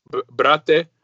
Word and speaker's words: brate: Brate.